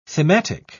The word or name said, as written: thematic